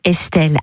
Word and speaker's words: ESTL: Ça s'écrit en quatre lettres. E-S-T-L et ça se dit Estelle, artiste qu'on adore en cette fin d'été avec Ici Bourgogne.